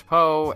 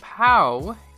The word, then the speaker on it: PAO: It is spelled P-A-O, and for the life of me, I want to call it the Nissan PO, but I guess I'm just a Kung Fu Panda fan, because it is pronounced PAO, not PO, and so throughout the episode, I will make sure, or at least try to, call it a PAO.